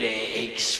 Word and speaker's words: Aiax: I thought we'd name this song Aches. You would spell that A-I-A-X. And it's pronounced like his heart feels. It aches.